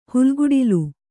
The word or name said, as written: ಹಾಲ್ಗಡಲು